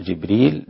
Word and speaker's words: جبريل: اللي بيقرأ جبريل جبريل، جبرائيل جبرائيل، هكذا.